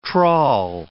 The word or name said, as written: trawl